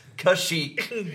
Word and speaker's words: Kashyyyk: I finally found an excuse to correct Andrew's pronunciation from earlier. I can tell you where Chewbacca does live. Kashyyyk.